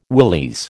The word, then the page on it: willies: American English